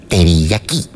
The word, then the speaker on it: teriyaki: Bueno, también está la hamburguesa que preparan en Japón con ingredientes como salsa teriyaki. Así se pronuncia. Teriyaki.